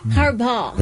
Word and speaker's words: Harbaugh: Harbaugh.